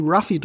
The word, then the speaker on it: Luffy: Die deutsche Synchro war eigentlich sehr clever, Monkey D. Ruffy draus zu machen, weil das näher, phonetisch näher klingt im Original, als das Monkey D. Luffy, was halt, das L wird ein bisschen mehr wie ein R ausgesprochen im Japanischen, wenn ich mir das hab richtig erklären lassen, ne?